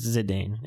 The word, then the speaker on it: Zidane: That's what they called him? I thought it was Zane in the games. Maybe it's spelled Z-I-D-A-N-E. It's possible that they pronounce it Zane, but... – No, I'm probably wrong. I haven't touched anything from Final Fantasy IX. Really. Yeah, it's Zidane. It is Zidane.